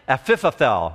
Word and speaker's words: Ahithophel: David had a confidant, an individual, I can barely pronounce his name, it's an ancient Hebrew name, Ahithophel or something like that. Let me spell it for you. A-I-H-I-T-H-O-P-H-E-L. You try it. Ahithophel, okay?